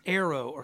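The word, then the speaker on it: airō: The Greek word for takes away, the Greek phrase there for takes away, is the word here for arrow, like A-I-R-O. It's how we would spell it in English. It's arrow, okay? Like eros or arrow.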